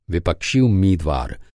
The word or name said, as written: विपक्षी